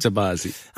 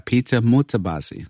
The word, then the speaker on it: Mutabazi: Let me see if I can say his name right. Peter Mutabazi. No? – Yes. No, you got it right. – All right. – Mutabazi. – I mean, I wanted to get the accent right. – No, you didn't get that part right. – When you just said it a minute ago, I was like, I'll never be able to say it as cool as you just did. Peter Mutabazi? – Yes, you said it right.